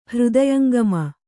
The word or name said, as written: ಹೃದಯಂಗಮ